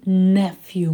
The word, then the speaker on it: nephew: И nephew, моя хорошая, nephew, через звук «э».